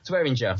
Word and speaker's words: Swearinger: So congratulations to the winners of Matt Ryan and Le'Veon Bell, Jason McCourty, and you're going to help me out with the pronunciation, DJ Swearinger? – Swearinger. – Swearinger, thank you.